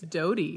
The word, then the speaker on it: Doughty: Is it Doughty or? – It's Doughty. – Not Doughty. – No, not Doughty. – Okay, good. – Like bread dough t-shirt.